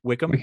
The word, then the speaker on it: Wycombe: The winner would go on to play the winner of Tottenham versus Wycombe. I believe it's pronounced. I may be wrong. – Wickham. – There you go.